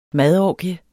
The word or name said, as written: madorgie